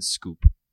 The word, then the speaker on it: Schoop: Jonathan Scope. – Can still hit homers, sure. – Jonathan, hold on. Let me see if I know how to spell it. S-C-H-O-O-P? – Correct.